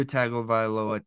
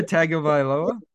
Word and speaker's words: Tagovailoa: I'm sorry, can you try the last name there again? – Did I say that wrong? – I just want to hear you say it again. – Chris Greer? – No, not that one. – Yes, nailed it. – Tua Tagovailoa? – That's better.